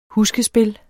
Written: huskespil

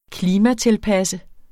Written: klimatilpasse

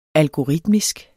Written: algoritmisk

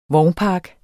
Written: vognpark

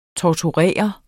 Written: tortere

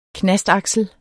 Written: knastaksel

